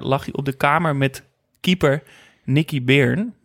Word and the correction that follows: Byrne: Beern? Beern? En dan denk je... – Hoe zou jij dat uitspreken? Byrne? Byrne.